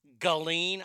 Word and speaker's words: Ghislaine: And it's, I guess her name is pronounced Ghislaine. I guess the S is silent.